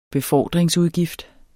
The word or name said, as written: befordringsudgift